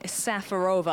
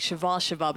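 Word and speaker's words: Šafářová: and she is partnering the Czech lefty Lucy Safarova. I think we all say that wrong. It's actually Shavashova, but she was known as Lucy Safarova, and alongside her final run at Roland Garros in 2015, she made the semis here in 2014.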